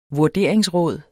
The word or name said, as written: vurderingsråd